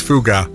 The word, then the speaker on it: Foghat: do you pronounce it Foggart or Foggart? – Foggart. – Foggart. – Foggart. – Like nougat. Nougat. – Yeah. – Nougat. So this is Fougar.